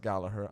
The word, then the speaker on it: Gallagher: They stopped saying Gallagher. It's pronounced Gallagher. I get it. And Gallagher even said that his family prefers people get it right.